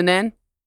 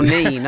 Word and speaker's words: Nene: Biggest fish you've caught in the Nen? Because I say Nen, you can tell you're near Thrapston because you're Nene, aren't you? So that's about where the pronunciation changes.